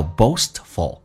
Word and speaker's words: boastful: 来看一下 You are 美式发音，打开口腔卷舌 are You are One more time You are You are so 先发 a 再发 u 下巴非常的夸张 So You are so boastful 在音标当中有一个 T 叫 boastful 但是我们发现这样发的会比较啰嗦一点所以通常为了偷懒 T 一般不发音叫做 boastful L 这个发音怎么发舌尖抬起来抵住你的上齿背叫 oh full boastful